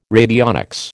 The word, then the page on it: radionics: American English